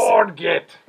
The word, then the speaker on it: orgeat: I just learned how to pronounce orgeat today because I've been saying it wrong for the entire 20. – It's orgeat.